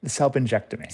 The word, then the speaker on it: salpingectomy: salpingectomy.